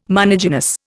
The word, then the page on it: monogynous: Indian